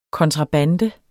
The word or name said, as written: kontrabande